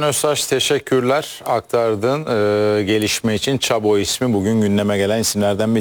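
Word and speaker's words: Chabot: Babası Alman, annesi Fransız. 5 dil birden konuşabiliyor. Çabo. O yüzden de ismi Çabo diye okunuyor.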